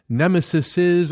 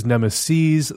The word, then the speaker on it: nemeses: And finally, Dennis Grace tweets, in an ad on the Savage Lovecast episode 750, "You, Dan, said nemesis or nemesi. The plural of nemesis is nemeses, the last syllable rhyming with peas.